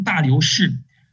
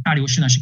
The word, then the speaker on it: 大流士: 哈该书的背景呢，我们刚刚讲到说是在波斯王大力乌哈的时候，大力乌呢又称大流士。那个大力乌呢，是我们根据亚兰文的原文发音发出来的哈。大流士呢是根据希腊文的发音来说出来的。